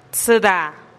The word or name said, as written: tsïda